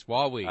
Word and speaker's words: Huawei: We bake for instance, one of the big things with Huawei, which is the largest cell phone manufacturer and the largest cellular equipment. – Huawei or Huawei? – I heard it today. – Huawei? – It's Huawei.